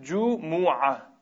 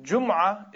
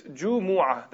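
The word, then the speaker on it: جمعة: The day, this Friday, in Arabic, we call it Jumu'ah. That's a correct pronunciation. Jumu'ah is wrong. So many people, they read this name with a sukun on the meme, that's incorrect. It's Jumu'ah. That's the correct pronunciation of the name of the day.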